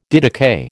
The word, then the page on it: didakai: American English